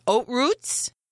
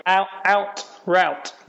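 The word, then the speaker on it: Haute Route: I saw the first posting for, is it oat routes? – Yeah, very good. – Thanks. – The Americans normally say out route, like nearly the complete opposite. But yeah, in French it's oat route.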